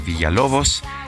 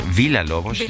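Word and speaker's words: Villa-Lobos: No quiso esperar al 13 de diciembre, cumpleaños de Taylor Swift, y dijo, yo pues iba a proponer a Héctor Villalobos. Eh, Villalobos. Villalobos es la pronunciación portuguesa, pero pues no, no se hizo que nuestros amigos del auditorio están disfrutando la música y eso me da mucho gusto.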